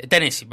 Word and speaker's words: Tennessee: Sí, mañana 30 de septiembre en Tennessee, perdón, así se pronuncia de mejor manera.